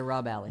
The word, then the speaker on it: Rob Alley: And you're Rob Alley. – Rhyme with Valley.